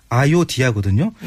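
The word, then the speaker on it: Ayodhya: 이 아유타의 인도 발음이 지금의 인도 발음이 아요디아거든요.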